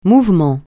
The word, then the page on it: mouvement: francais